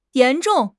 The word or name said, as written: yan2 zhong4